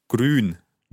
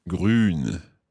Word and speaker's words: Grün: Grün. – Grün. – Has the Ü sound again. Grün. – Grün.